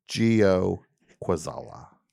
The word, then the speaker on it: Joe Kwaczala: Now, this guy, people have a hard time pronouncing his name, but I can do it. Gio Kwaczala. – You nailed it. – Right? – Yeah. – Gio. – Gio, yeah. You know, most people see, they see Joe, they the J-O-E, they think they know it.